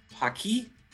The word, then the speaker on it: PaQui: We have John Chappell, CEO of PaQui.